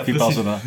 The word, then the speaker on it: Vipassana: Nou, dat woord kan ik nooit uitspreken. Vispannen. Ja, Vipassana. – Vipassana.